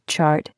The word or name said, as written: chart